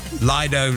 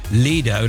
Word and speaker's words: lido: Claire on Instagram said, in Stroud, it's pronounced outdoor pool or Lido like Dido. However, I have heard Lido like Speedo, and it always makes me chuckle.